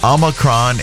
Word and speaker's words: Omicron: Officially, almost anything works. Both Omicron and Omicron are fine. The Oxford Dictionary includes Omicron. And in classical Greek, it was pronounced with the second syllable sounding like me.